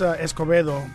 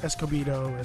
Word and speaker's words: Escobedo: Yeah, it's Escobedo, but I hate it when people like get the Spanish word and they stop to really pronounce it properly. So Escobedo, Escobedo.